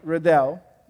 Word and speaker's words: Riddell: You see, my name, Riddell, it doesn't even sound like my name in English. No one can pronounce it right, especially JD. You know, he sounds sort of like a Russian drill sergeant. Rodel, you know.